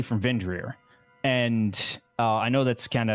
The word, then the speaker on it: Vindhyryr: Vendrir. It's spelled V-I-N-D. H-Y-R-Y-R.